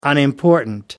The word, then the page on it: unimportant: American English